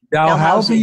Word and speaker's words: Dalhousie: Dalhousie University, 1978.